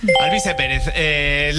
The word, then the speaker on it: Alvise Pérez: Bastante mal pronunciado, pero es correcto. – Al Baize Pérez. – Alvise Pérez.